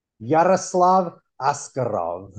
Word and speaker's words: Yaroslav Askarov: Yaroslav Askarov.